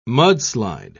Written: mudslide

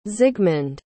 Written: zigmund

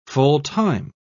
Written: full-time

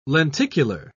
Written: lenticular

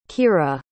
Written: kira

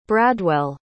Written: bradwell

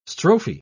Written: strophe

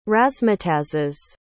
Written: razzmatazzes

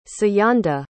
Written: siyanda

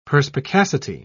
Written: perspicacity

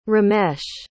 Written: Ramesh

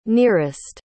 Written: nearest

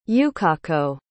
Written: Yukako

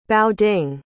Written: baoding

